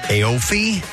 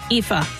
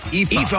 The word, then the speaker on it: Aoife: And the person, now, I am not sure how to pronounce their name, but it is spelled A-I-O-F-E. Aofi? I doubt it. So if anyone Aoife. Irish, what's that? – Aoife. – Aoife. – All right, Aoife Byrne.